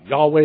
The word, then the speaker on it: Yahweh: This is a tetragrammaton. It's pronounced Yahweh.